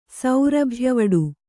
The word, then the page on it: ಸೌರಭ್ಯವಡೆ: kannada